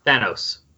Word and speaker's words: Thanos: How do you say his name correctly? – Thanos. – Thanos. But we used to always say Thanos. – Yeah, because it's based on the name of like a Greek god of death, Thanatos.